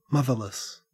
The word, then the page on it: motherless: Australian English